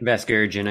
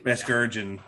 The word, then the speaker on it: Vasgersian: That's how you say it? – Vasgersian, I think. – Vasgersian, okay. And no more A-Rod, please.